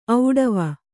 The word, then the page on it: ಒಡವು: kannada